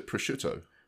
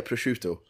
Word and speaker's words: prosciutto: What's the Italian for ham? Can you remember? – It's a prosciutto. – Yeah, prosciutto.